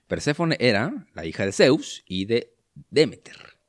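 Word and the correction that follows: Deméter: No, tiene el acento en otro lugar. Demeter.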